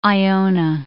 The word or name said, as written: Iona